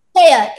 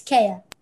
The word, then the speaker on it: Kea: I hope I'm pronouncing your name right. – Kea, it's Kea.